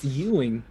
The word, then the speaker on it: Ewing: It's pronounced E-W-I-N-G. We'll not pronounce it spelled. – I think it's Ewing.